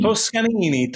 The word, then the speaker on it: Toscanini: Toscanini. Toscanini. Toscanini. You pronounce every syllable.